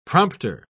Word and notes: prompter: British English

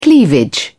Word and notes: cleavage: British English

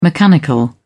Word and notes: mechanical: British English

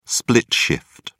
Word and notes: split shift: British English

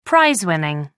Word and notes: prize winning: American English

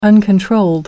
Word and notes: uncontrolled: British English

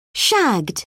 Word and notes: shagged: British English

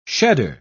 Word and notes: shedder: British English

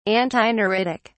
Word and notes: antineuritic: British English

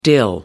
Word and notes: dill: American English

